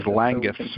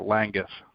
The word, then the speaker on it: Langguth: It is Langguth, sir. Langguth.